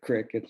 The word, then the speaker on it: creek: And also too, do you call it a crick or a creek? – I think that's, I grew up with some people who called it crick. That's a country pronunciation. Urban rural thing.